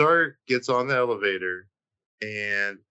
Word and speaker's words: Mizar: So we see Mizar. I said it right. – Mm-hmm. Hey, you got it right that time.